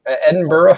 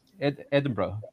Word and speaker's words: Edinburgh: The reason I was curious, I just realized I actually have a friend I think who lives in Edinburgh. How do you say that name? – Edinburgh. – Hey. Edinburgh. Yeah. Mm-hmm.